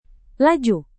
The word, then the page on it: laju: indonesian